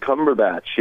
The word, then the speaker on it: Cumberbatch: Benedict Cumberbatch, yeah.